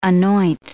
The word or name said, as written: anoint